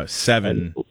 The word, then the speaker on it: Sevin: It's it it's seven, but it's spelled wrong. It's S E V I N.